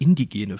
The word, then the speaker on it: indigene: Und zweitens, ich glaube, ich habe eben die ganze Zeit indogene Völker gemacht. Ich meine natürlich indigene Völker,